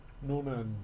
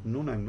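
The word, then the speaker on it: Noonan: Por ejemplo, la de Amelia Earhart y Fred Norman o Nunan, ¿cómo se pronunciará eso? Nunan. Nunan, ¿no? Ajá.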